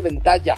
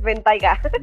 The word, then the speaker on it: Bentayga: Aiga, ventaiga, no se dice ventaiga, se dice ventalla. – No, sí es ventaiga, esta vez sí es ventaiga.